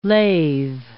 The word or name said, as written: lathe